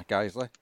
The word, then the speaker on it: Guiseley: I did pronounce that right, didn't I? Guiseley? – Yeah. – Good.